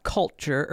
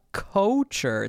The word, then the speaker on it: Couture: There's an album called Robot Culture, or no, it's not culture, it's Robot Couture. It's spelled C-O-U-T-U-R-E.